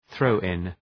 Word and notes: throw in: ‘ɵrəʋ,ın

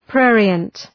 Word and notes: prurient: ‘prʋrıənt

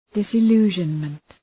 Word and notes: disillusionment: ,dısı’lu:ʒənmənt

